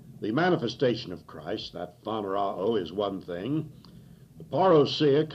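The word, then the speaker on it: phaneroō: that's spelled P-H-A-N-E-R-O-O, and the first O is short, like in box, and the last O is long, like in moon, and we would pronounce it phanerao. P-H-A-N-E-R-O-O.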